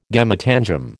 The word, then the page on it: gametangium: American English